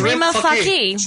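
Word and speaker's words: Rima Fakih: How do you say her name? – Rima Faki.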